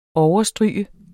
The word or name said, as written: overstryge